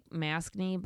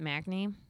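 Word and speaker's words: Macnee: I think that's how you pronounce it, like Maskney, but Macney.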